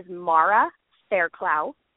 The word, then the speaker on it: Mara Fairclough: So my name is Mara Fairclough, and it's funny that you say that, because I've gotten the funniest.